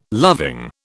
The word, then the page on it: loving: British English